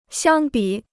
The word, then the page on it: 相比: xiāng bǐ